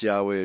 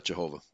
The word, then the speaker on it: Yahweh: So, okay, it should be pronounced Yahweh and not Jehovah.